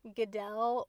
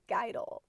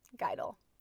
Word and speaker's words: Geidel: So my name is Paige Geidel, and a little fun fact about me is that most people think my last name is actually pronounced Geidel or Geidel, but it's actually Geidel, like if you say a dull guy and then reverse it, Geidel.